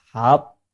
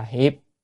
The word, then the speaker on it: hiệp: Thì tiếp theo làm gì, hiệp vi nhất hoa, chỉ chữ này đọc là hợp hay là hiệp, hợp lại hay là hiệp lại, nó hợp lại thành, vi là thành, nhất hoa là một hoa.